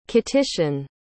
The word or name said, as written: Kittitian